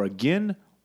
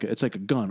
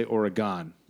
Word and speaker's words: Oregon: And then people from Oregon, how it's spelled, some people will say Oregon, or a gun, because one guy was like, no, it's like a gun, or a gun. – But it's past tense. – Nobody can agree how you pronounce it, except they can all agree that you don't say Oregon the way it's spelled.